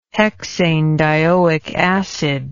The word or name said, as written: hexanedioic acid